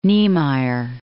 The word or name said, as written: Niemeyer